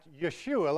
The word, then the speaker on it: Yeshua: It should be like Jaromir Jagr, but it's Jaromir Jagr, and it's the same here, where Joshua, for us English speakers, is actually pronounced Yeshua, like it's a Y at the beginning.